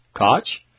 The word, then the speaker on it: Koch: K-O-C-H. Koch? – Koch.